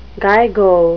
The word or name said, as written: gigo